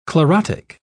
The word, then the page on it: chlorotic: British English